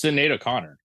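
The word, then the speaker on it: Sinéad: I didn't know you pronounced her name Sinead. It's like Sinead. – It's Sinead O'Connor. – I did see that headline. I have no idea who that is still. – I always thought it was Sinead O'Connor. I don't know. Maybe I just said it wrong.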